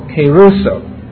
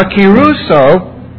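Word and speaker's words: keruso (κηρύσσω): The second word is the word keruso, K-E-R-U-S-S, long O, keruso. Now keruso has the emphasis up here, you see, is to announce good news.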